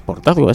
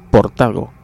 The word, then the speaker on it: Portago: Y siempre lo he conocido como Marqués de Portazgo, aunque no es Portazgo, es Portago.